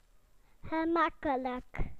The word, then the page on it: easy: British English